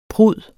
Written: prud

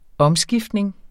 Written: omskiftning

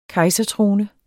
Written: kejsertrone

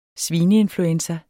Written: svineinfluenza

